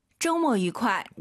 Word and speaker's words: 周末: 周末愉快. – 우와, 엄청 빠르게 발음하셨어요. 맞아요. 이 뜻의 특성상 좀뭐 愉快 이렇게 안 하죠. 다시 한번. – 周末愉快. – 오유는 어예요. 쪼가 아니라 쩌우, 모가 아니라 모어, 쩌우 모어.